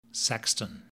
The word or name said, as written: sexton